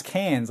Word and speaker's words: Cairns: But the one that always annoyed me was this Cairns in Queensland, because it's spelled Cairns. And then it's like, no, it's Cairns. Like, they just emit, like, two letters.